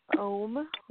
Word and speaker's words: Ogham: I've also heard it pronounced Om. It definitely looks like Ogham, but I've heard folks pronounce it as Om, and it supposedly goes back to the Picts of Scotland, but we can't really know that for sure.